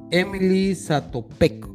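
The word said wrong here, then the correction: Emil Zátopek: Se llama Emil Satopec, como dices tú.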